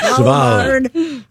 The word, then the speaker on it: Svalbard: In Norway's Svalbard? Is that how you say that? – Svalbard.